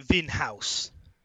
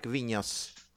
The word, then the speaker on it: Vinhos: I always want to call it Vinhouse, but no, yeah, it's a Portuguese word. It's a Portuguese word. Thank you. – I think that N and H are pronounced the N, like Vinhos.